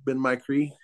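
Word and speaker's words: Ben-my-Chree: So, but this place, Ben Micree, is just it's just something from the past. It's absolutely, it's spelled B-E-N, my, and Cree, I think, C-H-R-E-E, Ben Micree.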